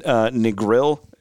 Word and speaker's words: Negril: We were in Negril. Is that that's how you pronounce it, right? – As far as I know.